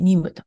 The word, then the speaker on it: NYM: で、ニム、えー、 NYM と書いてニムと、えー、テクノロジーズというところが 300 ミリオン。